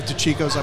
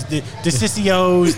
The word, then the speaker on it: DeCicco's: She's like, and she can never say the name right. It's always DeCicco's, DeCicco's. I'm like, Ma, what's going on? – There's a shortcut. You got to grab the cheek. DeCicco's.